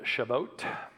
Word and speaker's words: Shavuot: It's called Shavuot. That's the Hebrew pronunciation of it.